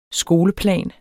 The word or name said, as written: skoleplan